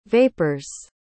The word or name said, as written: vapor's